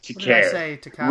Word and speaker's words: Takei: I presume you mean George Takei. Takei. Rhymes oh, with gay.